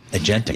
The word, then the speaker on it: agentic: Agentic. Yeah. All right. – And also, remember, how you say it? – Agentic? – Agentic AI. – Agentic. That's what our folks here say.